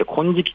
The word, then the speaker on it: 金色: そして最後はですね、これ金色の風と書いて金色って読むんですか、金色の風という岩手県産のものがあるんですね。